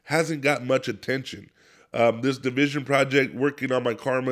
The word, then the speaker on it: DVSN: So the project I want to talk about this week is from Division, and Division is spelled DVSN, and it's called Working on My Karma.